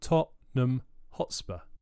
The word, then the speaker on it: Tottenham Hotspur: In Tottenham Hotspur, the N is swallowed. It's pronounced Tottenham Hotspur.